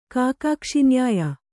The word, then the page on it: ಕಾಕಾಕ್ಷಿನ್ಯಾಯ: kākākṣi nyāya